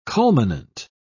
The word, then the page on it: culminant: British English